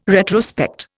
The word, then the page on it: retrospect: Indian